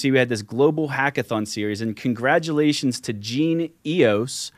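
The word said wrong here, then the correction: GenOS: Yeah, I believe it's Gene OS. So it has EOS in the name, but they pronounce it Gene OS, like operating system.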